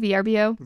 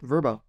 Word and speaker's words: Vrbo: VRBO? – Vrbo.